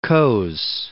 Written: Coase

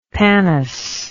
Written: pannus

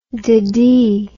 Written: deadee

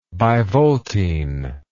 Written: bivoltine

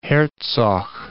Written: Hertzog

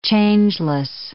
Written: changeless

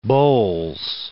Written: Bowles

